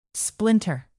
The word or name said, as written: splinter